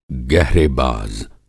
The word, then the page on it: गहरेबाज़: Hindi